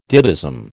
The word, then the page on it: ditheism: American English